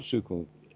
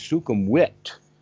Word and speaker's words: Sukhumvit: except that it's not Sukhum, it's Sukhumvit, because they have the V sound is said like a W.